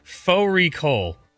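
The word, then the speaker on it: fårikål: Faux recall, which is a mutton and cabbage. It's spelt like furry call.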